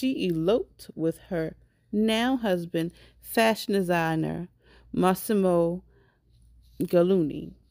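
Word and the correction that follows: Giannulli: Gianluni? I said it? No? – Giannoli. – Giannoli. Giannoli.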